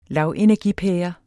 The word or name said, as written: lavenergipære